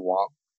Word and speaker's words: Wang: So I started a YouTube channel under my name, Tom, W-A-N-G. By the way, it's spelled, so it's W-A-N-G. In Chinese, it's pronounced Wang. I know we're all in this pronunciation game today. No, Budapest, Guangzhou. Now it's my last name. But yeah, Wang, W-A-N-G, or Wang, that's pronounced here in Canada and USA.